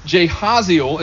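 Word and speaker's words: Jahaziel: And I think it's Jehaziel is how you say his name.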